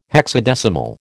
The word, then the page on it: hexadecimal: American English